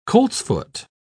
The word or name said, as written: coltsfoot